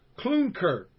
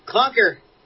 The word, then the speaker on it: Klunker: Oh, it's Klunker. Okay, Klunker. It's spelled... – XIX is a song. – Yeah, XIX, okay. See, sometimes just the spelling don't get it over. You got to hear it from the dudes.